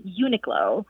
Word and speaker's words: Uniqlo: So, for example, there's a Japanese clothing store found across the U.S., and for years I thought the English pronunciation was Uniqlo. And then I overheard a friend who has a very keen ear for pronouncing words, and she pronounced it slightly differently with Uniqlo. And so I went online and found that that second pronunciation is actually the more correct pronunciation.